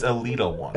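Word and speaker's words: Elita One: You just hurt my soul because you mispronounced one of their names. – What? – Go ahead. – Which one? – It's Alita 1.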